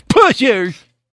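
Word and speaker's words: Pusser's: You're supposed to say it like that, just like when you say, we have Pusher's Rum. It's Pusher's!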